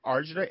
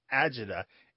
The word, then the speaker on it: agita: Usually these days it is spelled A-G-I-T-A, but you might see it as Argida, A-R-G-A-D-A, or Agida, A-G-I-D-A. And the reason there's some variation in pronunciation and spelling is that for a long time it was only transmitted orally.